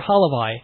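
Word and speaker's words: halevai: Alavai. – Alavai or halavai? – Halavai. – With an H.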